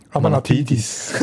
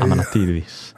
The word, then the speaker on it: Amanatidis: Von Eintracht Frankfurt, Amatidis. Amanatidis. – Das ist jetzt nicht so ja, ja, aber darum tust du noch das richtig betonen. – Amanatidis.